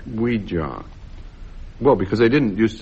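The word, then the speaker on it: Ouija: I think of an Indian mystic called Weejar. – Yeah. – I gave it the alternative pronunciation because it really does rather help. – Oh, yes, yes. It's known as a yes, yes.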